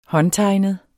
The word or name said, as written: håndtegnet